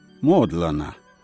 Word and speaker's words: Модлана: Модлана. Ударение ставят местные жители на первом слоге.